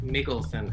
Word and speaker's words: Mikkelsen: And I think Mikkelsen, actually.